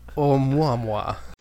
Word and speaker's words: Oumuamua: I think it's Oumuamua. – Oumuamua. – I'm going to apologize to all the Hawaiians out there for the pronunciation of this, because it is a Hawaiian word, but they think perhaps it is alien technology.